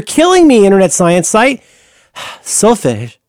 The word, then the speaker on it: solfège: One is, how I'm going to pronounce this, S-O-L-F-E-G-E with an accent, solfège? – Sure. – Solfège, because like in Spanish that means stress. – Mm. – I think this changes the tilt of the pronunciation.